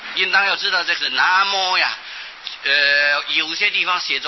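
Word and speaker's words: 南無: 从前那个南字啊，都是念作那，无啊就是摩，啊、就是本来这是客家话，有摩，有无就是有摩。那么本来这个无啊就是客家话的摩，啊、所以要念作南摩，应当是以客家话来念最正确了。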